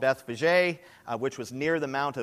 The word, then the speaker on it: Bethphage: 1a, now when they drew near to Jerusalem and came to, and it's actually pronounced Bethphage, like Fabergé, you've heard of that? Bethphage, and I was calling it Bethphage.